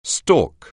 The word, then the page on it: stork: anglais